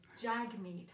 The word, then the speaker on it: Jagmeet: While we're on the short subject, Jagmeet. – Okay, Jagmeet.